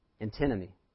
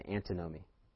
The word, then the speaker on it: antinomy: I've been saying it wrong the whole time. I had to go on Google and make sure I said it right. Antinomy. That's the correct way. I kept saying antinomy.